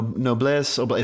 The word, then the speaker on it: noblesse oblige: No, noblesse oblige. It's French, not Latin, unfortunately.